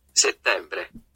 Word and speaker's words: settembre: Eller det ska uttalas så här, jag får ta det. – Settembre.